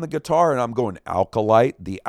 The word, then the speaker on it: Acolyte: Acolyte, am I saying that right, Will? – Yeah, of course.